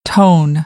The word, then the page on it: tone: American English; oʊ